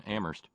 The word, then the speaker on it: Amherst: Meanwhile, Terry in Amherst, where only the H is silent.